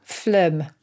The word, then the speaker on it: Phloem: Phloem. – Ooh. How do you spell that? – It sounds a bit like phlegm, which is why it's really not very nice. It's P-H-L-O-E-M. – Phlegm. P-H-L-O-E-M.